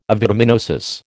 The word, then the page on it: avitaminosis: American English